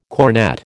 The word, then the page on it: cornett: American English